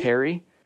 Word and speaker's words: perry: How do you pronounce it again? Perry? – Perry.